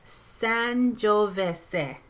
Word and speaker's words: Sangiovese: The last one is Sangiovese. And this is the correct pronunciation. Sangiovese.